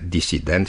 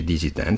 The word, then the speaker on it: disident: Ieri am vorbit despre formele disident și dizident și am spus că forma disident este cea corectă, fiindcă în limba franceză se pronunță dissident. Deci, așa să spunem și noi cu S.